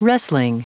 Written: wrestling

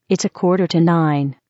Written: quarter to nine

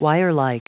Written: wirelike

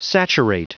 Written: saturate

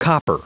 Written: copper

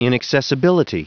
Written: inaccessibility

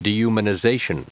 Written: dehumanization

